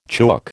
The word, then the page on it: chook: American English